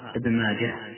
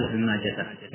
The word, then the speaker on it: ابن ماجه: عندنا هنا نقض ابن ماجه ونونه، والصواب في ضبطه أنه ينطقها ابن ماجه هكذا ولا يقال ابن ماجه يعني في حال الوصف، هذا هو الأصوب، مع العلم أن من جعله شاء فإن له وجه.